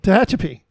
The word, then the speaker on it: Tehachapi: Tehachapi.